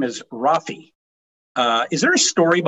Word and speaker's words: Rafe: The the proper pronunciation is is it's Rafe, but uh, got it. Rafe, my mom my got it.